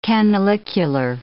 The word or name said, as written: canalicular